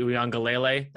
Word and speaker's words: Uiagalelei: I trust DJ Uiagalelei, that's the correct pronunciation, you're welcome, more than JT Daniels.